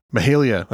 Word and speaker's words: Mahalia: Mahalia. – Mahalia.